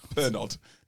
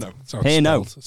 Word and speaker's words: Pernod: Pernod. – Pernod. Is that what it's called? – Pernod. – Spell Pernod. – Yeah. – Oh, okay. – D silent. – Silent D.